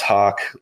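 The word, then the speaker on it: Haack: It's spelled H-A-A-C, pronounced Hawk.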